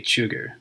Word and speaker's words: Cugir: It's from the state arms factory, Cugir. I think that's how you pronounce it. It's C-U-G-I-R.